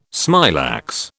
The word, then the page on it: smilax: British English